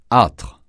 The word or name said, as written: âtre